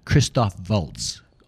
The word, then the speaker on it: Christoph Waltz: To go back, Christoph Waltz. – Oh, Christoph Waltz. – Right. – Yes, we must pronounce the name accurately.